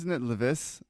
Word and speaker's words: Leviss: Isn't it Leviss that I found that out yesterday? Like, what the fuck, Leviss? – Yeah, that's what Andy said, yeah. – That's how you pronounce it.